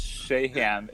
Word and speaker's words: Shahan: Yeah, it's Shahan. It's actually, you know, most of my childhood teachers and stuff would say Shahan. Uh-huh. And at some point, I thought, maybe it is Shahan. Maybe we say it. But then I found out it's from Irish. You know, when people came here and they changed the spelling. So a very common last name is in Ireland is S-H-E-E-H-A-N, Shay, and they pronounce it Shahan. – Ooh. – So that's where it came from. But they spelled it like S-H-A when they came, you know, the people at Ellis Island or whatever.